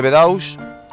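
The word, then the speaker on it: Bedaux: Aparece la figura de Bedous, que es quien coge el testigo de Taylor y desarrolla su sistema. – El método Bedous, pronunciado Bedo en francés, es un sistema que tiene por objeto la medida del trabajo teniendo en cuenta el tiempo de ejecución de una tarea.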